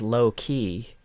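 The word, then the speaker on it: Loki: how does this pronounce? A lie? Oh god, subtle riot. – No, you don't understand. His name is Low Key.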